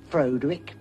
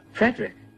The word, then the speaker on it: Frederick: Do you also say Frodwick? – No, Frederick. – Well, why isn't it Frodwick Frankenstein? – It isn't, it's Frederick Frankenstein.